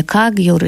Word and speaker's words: Кагьюр: Это собрание Кагьюр, или, как в России часто произносили в монгольском произношении, Ганджур.